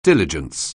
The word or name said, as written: diligence